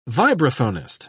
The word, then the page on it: vibraphonist: British English